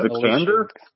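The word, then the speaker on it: Vikander: Alexandra Viklander, is that it? – Alicia. – Viklander? – Yeah.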